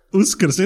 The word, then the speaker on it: Uskrs: Uskrs, it is Uskrs. – It is Uskrs. Yeah. It's spelled Uskrs.